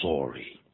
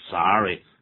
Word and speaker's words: sorry: 那么尤其是西部人家，但东部人呢，常常以他们自己的在美国东部人的英文呢为这个自豪，所以他们绝大部分在东部念成 sorry。那么在西部呢，中西部都念 sorry，两个都可以这样子，都可以这样子的。